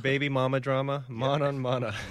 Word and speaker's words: manonmana: And it's called manan mana. And my Sanskrit isn't up to date, so it's a little hard to pronounce. But how is it again? – M-A-N-O-N-M-A-N-A. So it's a manan mana.